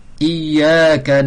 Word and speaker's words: إياك: Donc c'est pas de shedda, c'est pas ig, c'est pas i, c'est i, ya you i, i, iyaka, iyaka, iyaka, iyaka.